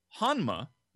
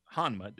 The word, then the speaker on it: Hanma: So I was probably saying Hanma, which is correct, right? Hanma. It's the N, not the M.